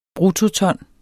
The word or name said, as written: bruttoton